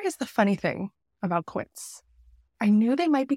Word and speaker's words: Quince: Quince, it rhymes with Prince, which I love because I am a huge Prince fan and also a very big Quince fan.